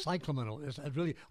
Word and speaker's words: Cyclamen: Cyclamen persicum? – Cyclamen. All you have to say is cyclamen.